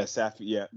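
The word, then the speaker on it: Safiullin: So they had that Roman Safulin. – Yeah, Safiulin.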